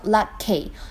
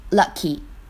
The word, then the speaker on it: lucky: lucky lucky，注意不要把它读成 lucky，因为末尾的话呢，应该是一个短元音的 e lucky。